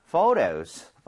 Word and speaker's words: photo: 그리고 참고로 ph는 f와 성질이 비슷하기 때문에 발음할 때 포토가 아니고요. photo 이렇게 부드럽게 해 주셔야 돼요.